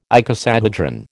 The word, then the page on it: icosahedron: American English